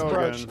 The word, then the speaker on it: brooch: Is it always I mispronounce it? – Yeah, it's brooch.